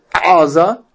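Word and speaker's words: Aza (עזה): And Ayin in Hebrew really is pronounced gutturally, like Aza. Aza, I can't really say it. It sounds like Gaza. That is Aza.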